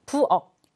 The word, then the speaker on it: 부엌: 부엌. 자, 키읔 받침이죠. 키읔이 그대로 발음이 나는 게 아니라 기역으로 교체가 되게 됩니다.